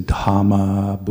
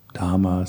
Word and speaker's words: Dhamma: And then H as in hot or have should always be pronounced. So do try to pronounce all H's in Dhamma, Buddha, Sangha. And that's always difficult because, as I said before, in English we usually just skip over H's when they're next to a consonant. So Buddha, Dhamma, Sangha.